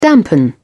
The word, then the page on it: dampen: British English